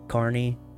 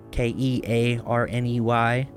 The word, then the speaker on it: Kearney: it's also pronounced like that, Carney, but it's spelled, you know, K E A R N E Y, which makes people who haven't been there want to pronounce it Kearney, and I assure you, is not.